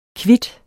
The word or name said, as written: kvit